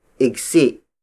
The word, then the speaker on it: igsi: Bangko, the Tagalog word for bench, is spelled as B-A-N-G-K-O. Ikli has two other variations, namely igsi. Igsi. Igsi, which is spelled as I-G-S-I.